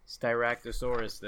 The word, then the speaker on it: Styracosaurus: And also the voice of the Styracosaurus in this movie. – Nice, good pronunciation. I'm glad you looked that up as well.